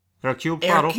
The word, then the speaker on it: Hercule Poirot: The ABC Murders, which we streamed, which is a pronounce it for me, Hercule Hercule Poirot. Hercule Poirot. There you go.